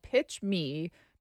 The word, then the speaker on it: Pitch MI: It's called Pitch Me, but it's spelled Pitch MI, utilizing our state initials there.